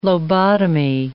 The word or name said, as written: lobotomy